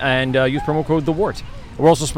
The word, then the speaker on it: Wort: We have a link directly to the our page, and of course, use promo code the Wort, WORT, pronounced Wort, spelled Wort, for your 10% off your order of Brewbag.